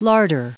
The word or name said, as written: larder